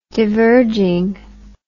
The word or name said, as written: diverging